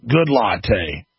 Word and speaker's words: Goodlatte: The letter was signed by the chairman of both congressional Judiciary Committee, Senator Chuck Grassley and Bob Goodlatte.